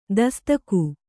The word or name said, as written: ತಸದೀಕು